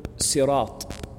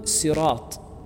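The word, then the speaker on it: صراط: For example, if we take the word sirat, sirat al-ladhina an'amta alayhim, the word sirat has been pronounced by the Arabs, and traditionally, with a sad and a seen and a zai, a zed. But the zed is not like a complete zed. It's a halfway, it's like a, what they call, I forgot what they call it, but it's a blend between a zed and a sad.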